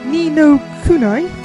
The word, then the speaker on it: Ni no Kuni: And also in that match, we had the world map theme from the upcoming PlayStation 3 title, Ni no Kuni, that's how I pronounce it, The Wrath of the White Witch, which is going to be incredibly awesome, by the way.